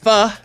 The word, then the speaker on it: pho: Pho. It's spelled P-H-O, but it's pho.